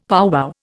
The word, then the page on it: powwow: Indian